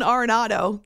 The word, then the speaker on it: Arenado: Nolan, I say it like different, like every third time I say it. Arenado. I should know this because it's an Hispanic name. So Nolan Arenado, there we go. That's how I should be saying it.